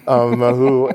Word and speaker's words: Mahu: I'm so glad that I pronounced it properly, Mahu, and tell the folks what that means.